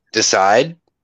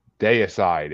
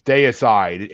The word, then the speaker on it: Deicide: You mean decide? – Deicide. It's Deicide. – It's spelled the same way as decide.